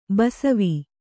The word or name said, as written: ಬಸವೆ